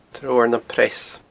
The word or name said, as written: treabhar nam preas